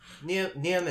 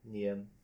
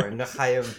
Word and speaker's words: Niem: Yeah, there's no standard way of pronouncing my last name in English. In Vietnamese, it's Neum, right? But who's going to pronounce that in English? – Well, I mean, we could try. – Yeah. Neum is fine. Like N-I-E-M. Neum.